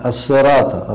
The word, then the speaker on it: الصراط: ترقيق الراء، الصراط، راء، لا الصراء، راء، راء، اهدنا الصراط، الراء هنا مفخمة لأنها مفتوحة.